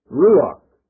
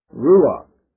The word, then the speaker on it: ruach: And that Hebrew word translated spirit there is pronounced in English, ruach, ruach.